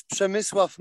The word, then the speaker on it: Przemysław: My name is Przemysław Malik, but since my name is very hard to pronounce, I'm guessing you can just call me by my last name Malik.